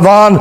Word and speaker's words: Vaughan: It actually looks like Vahan, but it's pronounced Vaughn, I understand. And I read, was reading a sermon by Vaughan,